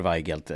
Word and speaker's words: Weigelt: George Weigelt har jag för mig att man ska uttala det. – George Weigelt är helt rätt.